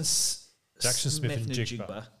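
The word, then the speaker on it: Smith-Njigba: So Jackson Smith and Jigba from Ohio State is the wide receiver number one. – You did pronounce that right.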